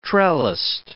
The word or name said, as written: trellised